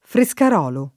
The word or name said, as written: Frescarolo